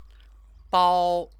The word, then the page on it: 包: bāo